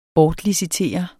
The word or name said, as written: bortlicitere